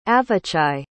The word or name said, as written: avichai